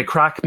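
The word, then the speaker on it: craic: And, oh, by the way, crack means fun in Irish. It's not, it's not methamphetamine. It's literally, it's spelled C-R-A-I for the C, and it literally means fun.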